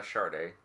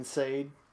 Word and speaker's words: Sade: I would say just about anything by Sade. – You mean Sade? – Sade. Mm-hmm. Sade. – Yeah, stop saying it wrong.